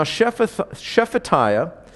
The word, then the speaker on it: Shephatiah: Now Shephatiah, the son of Mattan, Gedaliah, the son of Pashur, Jukal, the son of Shelemiah, and Pashur, the son of Malchiah.